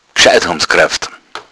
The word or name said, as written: raufen